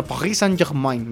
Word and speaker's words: Paris Saint-Germain: Bueno, Paris Saint-Germain me dijo.